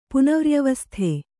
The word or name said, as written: ಪುನವ್ರ್ಯವಸ್ಥೆ